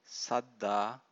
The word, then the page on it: saddha: Pali